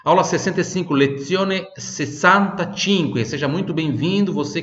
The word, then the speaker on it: lezione: Le forme geométrica, terça parte, repita, lezione, esse Z tem som de TS, lezione,